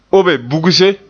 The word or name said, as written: obe bugse